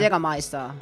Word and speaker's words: Jägermeister: Como se dice Jägermeister en alemán de verdade? – Jägermeister. – Jägermeister.